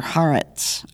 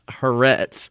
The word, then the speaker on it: Haaretz: Then last week I was contacted by someone in Israel who works for the newspaper Haaretz. I'm probably butchering the pronunciation. – I believe it's Haaretz, but then again, I could be butchering it too, so.